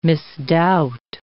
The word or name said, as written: misdoubt